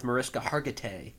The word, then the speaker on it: Mariska Hargitay: Well, it's interesting because I remember watching an interview with Mariska Hargitay. I hope I'm saying that right. And she, great pronunciation.